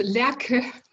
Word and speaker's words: Lærke: Yes, Lærke. So we pronounce the E in the end. That's what's different from English.